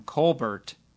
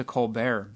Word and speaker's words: Colbert: Stephen changed the pronunciation of his last name from Colbert to Colbert because an astronaut told him to.